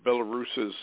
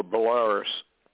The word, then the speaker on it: Belarus: and the Belarus's president, or Belarus. I keep saying Belarus, but it's actually Belarus, I guess. They pronounce it both ways.